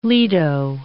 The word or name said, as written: lido